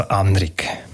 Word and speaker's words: Anrig: Wo dann aber das Sarganserland deutschsprachig geworden ist, hat man im Fall von Anrig die Betonung wieder auf die erste Silbe zurückverlegt. Heute sagt man also Anrig.